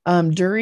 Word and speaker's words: Duerre: Duerre is Duerre. And it's pronounced, and you did great, Bridget, Duerre like jury.